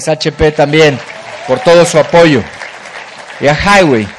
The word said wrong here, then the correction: Huawei: Siempre tengo duda con la pronunciación, pero está bien, Highway. Bueno, Huawei.